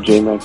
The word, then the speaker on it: J-Mac: You spell it T-H-O. C is in cat, U, and then Z. That's Phocuz. – Mine is J-Mac the Producer everywhere. J-M-A-C. T-H-E-P-R-O-D-U-C-E-R, and that's on all platforms.